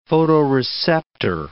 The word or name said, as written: photoreceptor